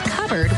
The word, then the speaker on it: cupboard: When we come back, why it's pronounced covered when it's spelled cupboard?